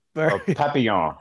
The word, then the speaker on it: Papillon: Papillion or Pep? – Papillon.